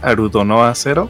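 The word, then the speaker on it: Aldnoah.Zero: Una serie bastante buena que yo les recomendé que se llama Aldnoa Zero, o como en su nombre lo pronuncian en japonés, Arudonoa Zero, la cual trata en un mundo postguerra, postapocalíptico, en el que unos chicos de, dejémosle que high school, pelean en mechas.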